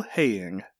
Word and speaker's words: Heying: Did you say his name was Kyle Haying? – Kyle Haying. H-E-Y-I-N-G.